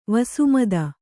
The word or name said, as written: ವಸುಮದ